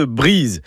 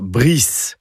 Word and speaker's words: Brise: Alors j'insiste bien sur la prononciation de Brise. D'ailleurs, écrit avec un Z, ça éviterait peut-être d'entendre dire Brice.